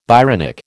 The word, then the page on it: Byronic: American English